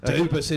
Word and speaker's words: Dubus: Like in France, I've my French family, and they would say Dubus. – Oh. – I would say Dubus, but my mum's side is Aussie, and they go Dubus. So you, as an Aussie, were quite correct.